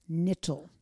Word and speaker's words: Knittel: Knittel. – The K is silent. – Silent K.